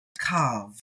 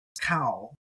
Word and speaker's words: καυ: Now, you may be, if you learned Erasmian, you may be learned to pronounce this as kau, but it's kav with the pointed Arab pronunciation, kappa, alpha, upsilon. That vowel pair, alpha, upsilon, is av, not al.